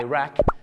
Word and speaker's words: Iraq: ชาวอังกฤษจะเป็นอิรักกับอิหร่านแต่ชาวอเมริกาเยอะมากครับจะออกเสียงว่า Iraq กับ Iran